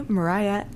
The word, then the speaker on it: Miraya: My name is spelled N-A-T-A-S-H-A, Mariah, M-I-R-A-Y-A.